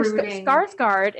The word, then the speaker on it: Skarsgård: I thought Alexander Skarsgård, if you dyed his hair black. – There you go. – Oh, yeah. I said it right. – Yep.